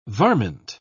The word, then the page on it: varmint: British English